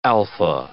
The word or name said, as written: alpha